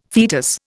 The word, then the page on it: fetus: Indian